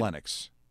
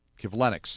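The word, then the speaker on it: Kivlenieks: Matisse, how do you say his last name? Matisse Kivinyx. That sound right? – Kivlenix. – Sorry? – Kivlenix. – Kivlenix. – Yes.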